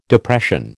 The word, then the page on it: Depression: American English